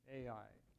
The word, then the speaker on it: Ai: His first name was Ai. The letter A, the letter I, pronounced Ai.